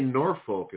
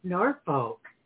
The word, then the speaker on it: Norfolk: Yeah, I say Norfolk is the proper way to say that word. – Norfolk.